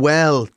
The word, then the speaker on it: bhuel: Well is something that's existed in the Irish language for a long time and is spelt B-H-U-E-L. And it drives... – Well.